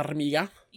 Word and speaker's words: Farmiga: Tessa Farmiga.